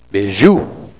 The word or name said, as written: bizhiw